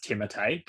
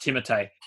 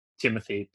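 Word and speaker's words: Timothée: You know his name's actually pronounced Timotei, but I just can't call him that. – Is it really? – It's actually pronounced Timotei.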